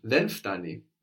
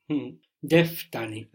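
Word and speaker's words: Then ftani (δεν φτάνει): Actually, in standard Greek, you will hear that N being dropped. The ftani. – De ftani. – De ftani. So it's not incorrect to say then ftani.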